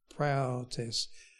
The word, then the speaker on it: praotes: In fact, in the translations, if you were to look up this word, and it is the word praotes. I can't say it exactly, Ricky, but it's praotes. It's a P and R A-O-T, long E-S.